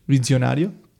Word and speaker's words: Visionario: How would you say that in Italian? – Visionario. – Oh, okay. So it's like you said it correctly? – Yeah, Visionario. Just the second S, or the first S is just Visionario.